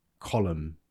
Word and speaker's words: Colm: Is it Colm? – Colm. Yeah, probably. – But I used to pronounce it Colm. – Colm. – Colm. – Colm. – You know, like calm. – As in the feeling. Word calm.